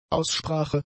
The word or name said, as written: Aussprache